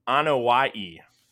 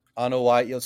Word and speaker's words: Anoa'i: So I believe the pronunciation is Anoa'i. – Anoa'i. So like it's like Hawaii.